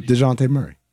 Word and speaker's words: Dejounte: DeJounte. – For the people, the uninitiated, how do we pronounce Murray's first name? – DeJounte Murray.